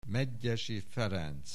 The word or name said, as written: Medgyessy Ferenc